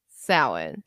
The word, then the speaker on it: Samhain: And I finally learned the right way to say Samhain. – Samhain. – Because I've always called it Samhain. – I think most people call it Samhain because that's how it's spelled.